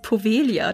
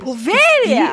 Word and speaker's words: Poveglia: Fangen wir mal mit Insel Nummer 1 an, nämlich Poveglia. Ich weiß nicht, ob es so ausgesprochen wird. – Ich habe es tatsächlich gegoogelt. Das heißt Povelia tatsächlich. – Povelia! – Die ist stumm.